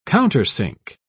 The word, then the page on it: countersink: British English